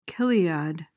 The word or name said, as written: chiliad